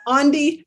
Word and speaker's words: Aundi: I often say like Gandhi, right? – Aundi Gandhi.